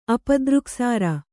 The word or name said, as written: ಅಪದೃಕ್ಸಾರ